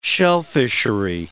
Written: shellfishery

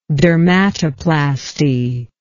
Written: dermatoplastic

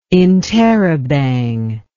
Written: interabang

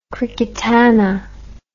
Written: cricketana

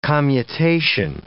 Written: commutation

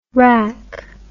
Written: WRAC

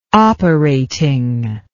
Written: operating

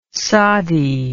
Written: sadi

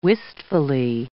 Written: wistfully

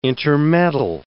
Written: intermeddle

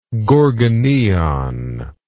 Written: gorgoneion

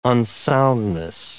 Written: unsoundness

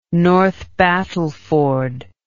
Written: north battleford

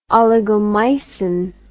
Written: oligomycin

